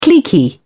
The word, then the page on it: cliquey: British English